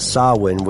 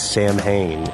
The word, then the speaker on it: Samhain: We talked about the origin of Halloween, the origins of Samhain, which I famously mispronounced as Samhain.